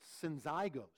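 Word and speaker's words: syzygos: It's pronounced synzygos. And it may have been actually a person in the church's name. And Paul may be saying, hey, I appeal to you, synzygos.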